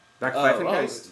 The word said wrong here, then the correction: Backpfeifengesicht: Oh, oh, no, it's Backpfeifengesicht.